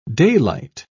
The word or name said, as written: daylight